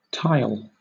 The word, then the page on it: tile: British English